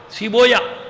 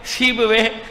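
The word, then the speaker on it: سیبویہ: لوگ اس کا تلفظ صحیح نہیں کر پاتے. بابوے کو بابویا پڑھتے ہیں، کیا پڑھتے ہیں؟ بابویا، سیبویا، قولویا، یہی ہیں. بابوے، قولوے، سیبوے.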